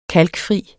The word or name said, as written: kalkfri